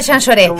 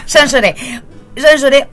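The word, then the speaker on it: Jean Jaurès: Y después, si vos querés, a las 8:30, en la Carpintería, que es la calle, se escribe Jean Jaures. Pero se dice Jean Lloré. Jean Lloré 858, que es Jean Jaures, Capital Federal.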